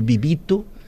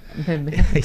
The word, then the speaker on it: Bebeto: era, no início, era, às vezes não pronunciavam bem, no mundo árabe era Romário Bibito. Bebeto.